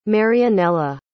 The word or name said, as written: marianela